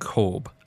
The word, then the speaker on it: Gaube: Lux Grand Corb. So that is spelt G-A-U-B-E for everyone playing at home.